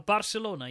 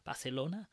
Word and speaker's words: Barcelona: Sorry, that's a terrible pronunciation. Barcelona. – There we oh, go. Oh, well, well, done that you should even go to the effort of pronouncing it properly.